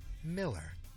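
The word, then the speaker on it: Millar: Don't let the spelling fool you. He pronounces it Miller.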